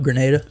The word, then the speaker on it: Grenada: Grenada? – Yep, Grenada.